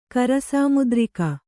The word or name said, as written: ಕರಸಾಮುದ್ರಿಕ